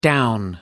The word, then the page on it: down: American English